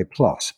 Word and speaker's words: plus: you say plus, pronounced that way, plus, P-L-U-S.